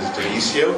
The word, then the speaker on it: Dionicio: First name is Dionicio. Do you want me to spell it? – Yes. – D-I-O-N-I-C-I-O.